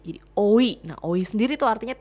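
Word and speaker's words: oi (오이): Nah, 2 Mei sendiri dipilih karena bahasa Korea pelafalan itu sama seperti kata timun itu sendiri, yaitu oi. Ya, 2 itu oi, Mei itu o.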